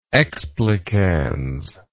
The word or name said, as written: explicans